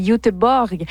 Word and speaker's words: Göteborg: Selon la prononciation exacte, ce serait Göteborg.